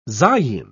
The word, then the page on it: zayin: British English